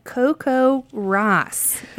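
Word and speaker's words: CoCoRaHS: Okay, this is called Coco Ross, and it's spelled in a very unique way. It's spelled Coco, like coconut, R-A-H-S, and it's short for the Community Collaborative Rain, Hail, and Snow Network.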